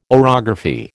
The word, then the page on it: orography: American English